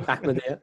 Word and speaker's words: Ahmadiyya: Ahmadiyya. – Ahmadiyya? – That's fine.